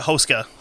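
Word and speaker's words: Houska: Uh, everything I've heard is called it Hoska. Hoska. – Yeah, that's what it was. – Because um, apparently, with Czech, with most words, you put the emphasis on the first syllable of the word.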